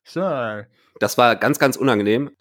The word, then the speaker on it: Searle: Wie spricht man diesen Nachnamen aus? – Searle. Dabei habe ich meinen Mund ganz komisch verzogen. Searle. Tom Searle.